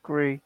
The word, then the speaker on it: Gris: Yeah, it's supposed to be pronounced Gris. – Gree. – I believe, but you know, we like to silence that consonant.